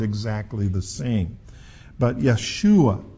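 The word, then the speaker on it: Yeshua (ישוע): And sometimes you will hear the name Yeshua. That's the Hebrew pronunciation of Jesus' name.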